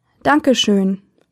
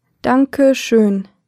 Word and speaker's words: Dankeschön: In Germany, thank you is Dankeschön. Dankeschön. Let's break it down by syllable. Danke schön. Now let's hear it once again. Danke schön.